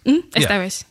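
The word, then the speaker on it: Estévez: Estevez.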